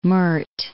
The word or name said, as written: Meurthe